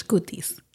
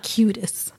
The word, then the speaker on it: cutis: The cutis, you would actually pronounce that in Spanish, cutis, not cutis.